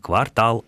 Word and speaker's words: квартал: Во всех остальных квартал, овал, либерал и так далее и тому подобное ударение будет ставиться только на последний слог.